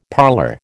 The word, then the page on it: parlour: American English